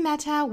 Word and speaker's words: matter: 首先呢，我们来看这个 matter 这个单词里面的这个 t 呢，不是一个的发音，也不是一个 t 的发音，而是一个的 flat t，轻轻的一闪而过。舌尖呢，在这个上颚的部分轻轻的一弹而过。